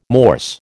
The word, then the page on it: Morse: American English